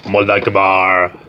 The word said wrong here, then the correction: Mullaghmore: It's pronounced Mullagh Mór. Yeah. Get it right.